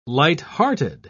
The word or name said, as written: lighthearted